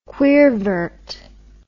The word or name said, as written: queervert